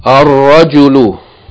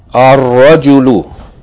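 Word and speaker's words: ar-rajulu (الرجل): The next word, ar-rajulu, ar-rajulu, meaning the man, the man. Here, ra is a sun letter, therefore, it should be doubled and the lam not pronounced.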